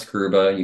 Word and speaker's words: Grubba: If you search for my name on the internet or Google it, Thomas Grubba, you can just put in my name, and that's spelled G-R-U-B-B-A.